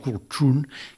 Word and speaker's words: cultrún: Un famosíssim és el cultrún, que s'ha de dir la T aquí pronunciada als alveols, cultrún, que és com ho diuen allà, i aquest bombo és molt important ritualment.